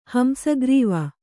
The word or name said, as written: ಹಂಸಗ್ರೀವ